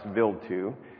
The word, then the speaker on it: Wild 2: It's spelled Wild 2, but it's called, pronounced Vild 2, that, you know, they think has been around for billions of years.